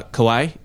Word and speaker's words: Kauai: I've been to uh, Kauai and mm-hmm.